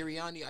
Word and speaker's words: Sirianni: That's how you pronounce it. Sirianni.